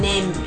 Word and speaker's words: neem: Y esta fruta es utilizada en la medicina ayurvédica, se escribe N-E-E-M, NEM, ¿ok?